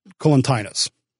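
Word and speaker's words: Collatinus: These two guys, his son, who's also named Tarquinius, this is why it gets confusing, and the other guy whose name is Colintius. I always mispronounce it, sorry. Colintinus.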